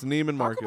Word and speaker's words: Neiman Marcus: Am I pronouncing that right? So it's Neiman? – It's Neiman Marcus.